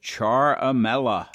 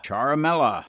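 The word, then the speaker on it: Ciaramella: It's pronounced Charamella. Charamella.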